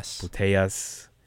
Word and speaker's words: Putellas: Putellas. – Sounds weird, Putellas, but first female to win back-to-back Ballon d'Ors.